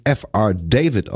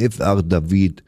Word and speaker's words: F.R. David: Ja, der wird tatsächlich nicht F.R. David ausgesprochen, der Mann jetzt kommt, sondern der hat das mal gesagt selber, hello, I'm F.R. David.